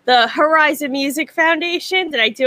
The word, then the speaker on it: Herizon: Herizon Music with the H-E-R because we are female-centric.